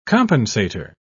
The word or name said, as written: compensator